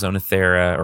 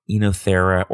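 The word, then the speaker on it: Oenothera: So I mentioned the pink evening primrose, Onothera, or Enothera, or Enothera, depending on how you want to pronounce it, speciosa.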